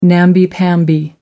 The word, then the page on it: namby-pamby: American English